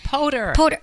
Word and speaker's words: Poter: I'm so excited to welcome my guests, Cam and Karen Lee Porter. – It's Potter.